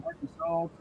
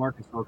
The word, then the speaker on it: Arkansas: Here and there and everywhere, all the way from Arkansas to Arkansas. You know, Kansas people say that people from Arkansas can't pronounce Arkansas. You know, they say it should be Arkansas. And I just say they're dumb.